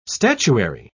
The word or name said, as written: statuary